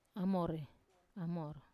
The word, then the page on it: amor: Wamesa